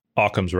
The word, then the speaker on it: Occam: It's Occard's razor, isn't it? – Occam's razor. – Occam, not Occard's.